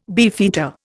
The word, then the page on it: beefeater: Indian